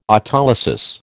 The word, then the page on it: autolysis: American English